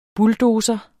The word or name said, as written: bulldozer